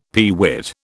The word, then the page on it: peewit: British English